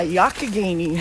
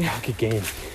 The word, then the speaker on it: Youghiogheny: Yeah, Youghiogheny. – Youghiogheny.